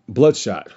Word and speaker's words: Bloodshot: Um, but we're talking about Wonder Woman 1984, the only live action comic book outside of Blood spot, blood spot. I completely butchered that. Um, ooh, it's a Vin Diesel movie. I know the character intimately. I've read his comics. I'm trying. That, that's gonna Bloodshot.